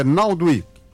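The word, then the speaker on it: Naaldwijk: Agora, deixando a brincadeira de lado, eu acho que a pronúncia certa é Naldwijk.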